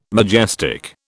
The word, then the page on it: majestic: British English